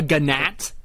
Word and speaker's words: gnat: A gnat. – A gnat. It happens. – The G is silent.